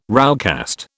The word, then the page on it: roughcast: British English